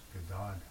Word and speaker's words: Gadadhar: On the memory tapes, Bhavananda was describing how when invoking the name Gadadhar, the Prabhupada insisted that it be pronounced Gadadhar.